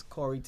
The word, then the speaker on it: Khouri: Like I said, you could hit me up on Facebook as Khouri T. Forbes. Once again, my name is spelled K-H-O-U-R-I.